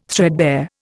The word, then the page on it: threadbare: Indian